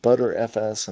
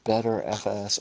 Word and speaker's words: Btrfs: Okay, and so when I went to the wiki, it says B-Tree file system, variously pronounced. It has lots of names, so it's called ButterFS, and that's what I heard on the Linux Action Show when they talked about it, or BetterFS, or B-Tree-FS, or simply B-Tree-R-E-F-S.